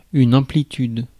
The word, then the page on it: amplitude: France; [ɑ̃.pli.tyd]